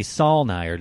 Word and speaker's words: Saulnier: Directed by Jeremy Saulnier.